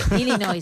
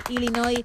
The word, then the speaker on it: Illinois: Illinois, sin la S. Así que, sin pronunciar la S final, Illinois, una vez más, tu apunte de geografía, que nos viene muy bien, porque si no lo tuviésemos ya nos quedaríamos raros.